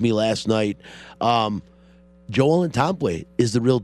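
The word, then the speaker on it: Ntambwe: And blazing, as you heard a Joel Tomboy three-pointer. That is Tomboy. The N is silent. All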